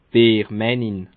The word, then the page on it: Biermännin: Bas-Rhin; Schiltigheim